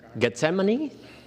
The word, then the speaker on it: Gethsemane: Nine, and when Jesus finished of praying, he and his disciples went to the Gethsemane.